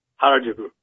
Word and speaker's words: Harajuku: Am I pronouncing this right? Harajuku? – Awesome. – What is it? – Pronouncing what, my name or Harajuku? – Yeah, Harajuku. – Harajuku, yeah.